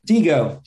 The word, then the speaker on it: DiGo: Or do I say Digo? – Digo. Digo for sure. Yeah, with the Italian pronunciation, Digo.